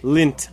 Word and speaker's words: Lindt: Lindt. I said it, like, the D is silent.